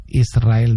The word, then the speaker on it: Israel: Y la S con la R es difícil de pronunciar. La gente dice Israel. Es sin verdad. Que suene la S, Israel, sí, es difícil pronunciar.